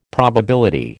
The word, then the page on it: probability: American English